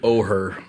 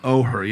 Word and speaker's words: Oher: I always say, is it Oler or Or? – Or. Yeah. It's spelled O-H. – Oher. – Oher, yeah. O-H-E-R.